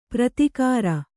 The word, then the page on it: ಪ್ರತಿಕಾರ: pratikāra